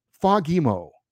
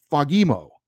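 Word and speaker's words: Fagemo: He texted me back that it's pronounced Fagimo, with the emphasis on the second syllable, Fagimo.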